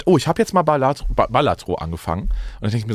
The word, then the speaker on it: Balatro: Ich habe jetzt gehört, das heißt Balatro. – Ah, okay. – Balatro. Ja, eigentlich wird es Balatro ausgesprochen.